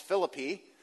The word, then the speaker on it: Philippi: The town of Philippi is also near the Canaan Valley in West Virginia, except there it's pronounced Philippi.